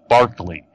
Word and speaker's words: Berkeley: If you read his name in print, it looks like Berkeley, but it's pronounced Barkley.